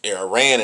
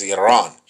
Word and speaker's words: Iran: It's Iran in English, but it's Iran. Iran. Let's pronounce it right. Get the right.